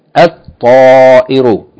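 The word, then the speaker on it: al-ta'iru (الطائر): Remember, the sun letters, when a word begins with a sun letter and you add alif lam, you don't pronounce the lam. Rather, you merge the lam into the sun letter and double the sun letter. So we don't say al-ta'iru, we say al-ta'iru, means the bird.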